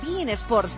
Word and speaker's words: beIN Sports: Gracias a Bein Sports, que sí, se pronuncia así, Bein Sports, el canal premium de fútbol internacional.